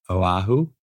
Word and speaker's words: Oahu: It's so good to connect here in Hawaii, in Oahu. Is that how we pronounce it? – Mm-hmm. Yeah.